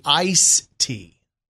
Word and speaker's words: iced tea: I-C-E-D. – Okay. – T. – Most people do say iced tea. – Okay. – Now, like almost 50% of people, but there's another part of the people that say ice tea.